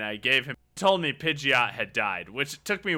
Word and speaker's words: Pidgeot: Is that the right way to say that, too? Because that's what I've always... It is, okay. Yeah. – Fun fact. I want to say one thing about Pidgeot.